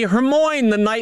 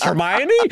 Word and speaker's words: Hermione: You know, for example, I pronounce Hermione Hermoine the first four books. Mentally, I pronounced it Hermoine until I went to a movie and I was like, wait, it's Hermione?